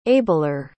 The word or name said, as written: abler